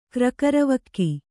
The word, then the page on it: ಕ್ರಕರವಕ್ಕಿ: Kannada